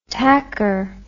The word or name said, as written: tacker